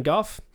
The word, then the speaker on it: Gogh: It's not Van Gogh. – So that's actually funny. I've actually looked it up in the past, and there are radically different ways of pronouncing it. The English tradition is Gogh. – There's probably entire scholars who did their entire PhD on which one it was. – Yeah. The French say Gog.